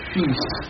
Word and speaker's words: fils: Évidemment, c'est fils. Que l'on dit et que l'on dit. – Ou fi. – Ah, fi, c'est l'ancienne prononciation. Je me souviens d'avoir entendu Paul Mounet, viens mon fi dans mes bras, viens... – Très bien, oui, c'est l'ancienne prononciation, n'est-ce pas, qui s'est conservée le plus longtemps dans la technique du théâtre.